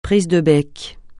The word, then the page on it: prise de bec: francais